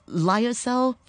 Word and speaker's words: lyocell: I understand that your shirts are made with lyocell fibers. Am I saying it right? – Yes, you are.